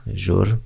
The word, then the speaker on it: Jour: Aplikacja się nazywa chyba Żur. To się czyta. Pisze się J, J jak Jadwiga, O jak Olek, U jak ulica Ryjak, e, Radosław, czyli Jour, Żur, Daily Self, care journaling, czyli od journal, o, czyli żur, czyli dziennik.